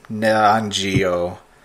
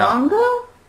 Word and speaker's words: Nyong'o: It's 2019 starring Lupita, and I'm going to butcher this last name, Nyong'o. What? – No. Nyong'o? – N-Y-O-N-G, apostrophe O. We'll call her Nyong'o.